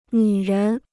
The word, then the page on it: 女人: nǚ ren